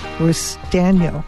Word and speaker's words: Restaino: She's a prosecutor, and we have Gary Restaino.